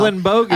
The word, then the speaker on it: Ellenbogen: You still got my name wrong again today. – Did I? – Yeah, you said Bogan. – Did I say Bogan? – Fuck yourself. – Ellenbogen.